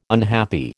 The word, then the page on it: unhappy: American English